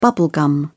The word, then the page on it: bubble gum: British English